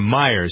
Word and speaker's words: Mayer: former Google executive Marissa Mayer. Or Meyer, some people are pronouncing her name.